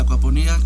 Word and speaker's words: acuaponía: Bueno, muy bien, la acuaponía con acento en la I.